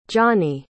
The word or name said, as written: johnie